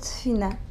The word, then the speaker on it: tefina: And we call it tefina.